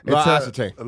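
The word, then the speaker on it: L'Occitane: Now I switched over to Loxetane. How do you say it? – What's that? – Loxetane.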